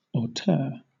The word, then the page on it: auteur: British English